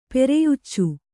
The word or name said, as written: ಪೆರೆಯುಚ್ಚು